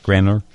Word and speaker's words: Granor: Is that how you say it? G-R-A-N-O-R? Is it Granor? – Yes, that's right.